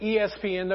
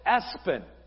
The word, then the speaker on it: Espen: You don't pronounce it ESPN, though. You pronounce it Espen.